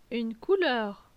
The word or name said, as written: Une couleur